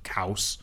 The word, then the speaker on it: Khaos: Hera, Nemesis, Cow, it's there's they want they're going with chaos, but it's spelled K-H-A-O-S, so like chaos, but Kaos.